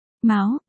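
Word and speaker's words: máo (苗): Ở đây chữ miêu trong miêu miêu, nhũ danh của Diệp Chi Hòa, là mèo nghĩa là lúa non, mầm, phát âm là máo, đồng âm với chữ mao nghĩa là con mèo.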